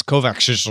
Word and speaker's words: Kovacs: I, I really like I, I almost said the name right. I just kind of like mumbled the very end of Kovac that's how they say it uh, in world Co- properly. If it's properly pronounced, it's Kovacs.